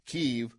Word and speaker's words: Kyiv: and I'm going to go with the Ukrainian pronunciation, Kyiv, that they're continuing to be hit even as we speak right now around 10:40 at night? – Well, they're having a very tough time there.